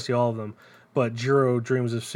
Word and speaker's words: Jiro: It is Jiro, but in a pronunciation I can't do because I'm a dumb American. – Got it. – But it is the hard J.